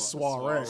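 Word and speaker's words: soirée: how you say it? – The soiree. – Soiree. – S W A Ray. – Yeah, soiree. You know.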